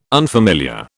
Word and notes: unfamiliar: British English

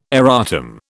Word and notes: erratum: British English

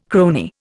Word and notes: crony: Indian

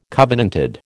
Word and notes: covenanted: American English

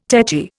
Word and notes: tetchy: Indian

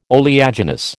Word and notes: oleaginous: American English

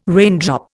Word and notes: raindrop: Indian

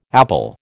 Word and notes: appal: American English

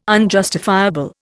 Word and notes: unjustifiable: Indian

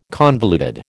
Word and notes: convoluted: American English

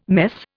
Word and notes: mess: Indian

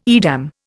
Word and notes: Edam: Indian

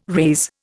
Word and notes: raze: Indian

